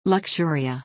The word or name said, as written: Luxuriou